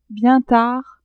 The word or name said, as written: bien tard